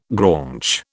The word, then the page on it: graunch: British English